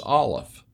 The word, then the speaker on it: Aleph: is Aleph, A L E P H, and pronounced almost like the word olive.